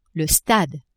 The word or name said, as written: stade